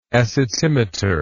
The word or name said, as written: acetimeter